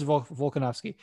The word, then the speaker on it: Volkanovski: I didn't say it right. I don't know why I put a P in there. Ortega versus Volkanovski.